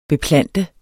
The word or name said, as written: BEPLANTET